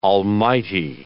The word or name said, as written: almighty